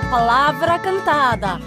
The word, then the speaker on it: Palavra Cantada: Estamos escutando a palavra cantada. Palavra cantada com V corta, hein? Não pronunciar palavra. Palavra cantada.